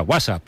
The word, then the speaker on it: WhatsApp: No, peor aún, con acento en la primera, WhatsApp, ¿no?